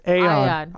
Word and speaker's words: Aeon: Eon Drive. – Did I say it right? – Eon? – Yes, Aeon. I would say Aeon.